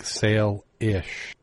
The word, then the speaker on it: Salish: and felt I suggest a correct pronunciation is pronounced with a long A, like sail-ish.